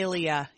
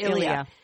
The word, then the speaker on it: Ilia: Oh, Ilia. Yeah. – The Ilia. – I-L-I-A. Ilia is, it is by far, and I've tried a lot of clean makeup brands.